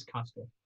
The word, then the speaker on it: Castor: So, Castor, I would imagine, is the pronunciation of that, although I happily, you know, look to be proved wrong, but I think it's Castor. – Yeah, no, I agree. That's my understanding as well.